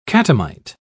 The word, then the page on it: catamite: British English